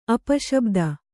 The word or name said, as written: ಅಪಶಬ್ದ